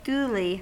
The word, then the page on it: duly: American English